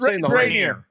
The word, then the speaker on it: Rainier: The beast then consumed about 36 cans of Rainier. – Wow. – Or is it Rainier? Am I saying it wrong? No, it's Rainier.